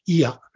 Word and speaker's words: iya: So let's look at a word, a Tagalog word, iya. It's spelled I-Y-A, and then it has a glottal stop at the end.